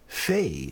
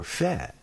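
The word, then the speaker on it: Fé: And this is by Leia Freire, F-R-E-I-R-E, and it's called Fe, or Fe, F-E. There's an accent over the E, so Fe would be a Jewish pronunciation, perhaps.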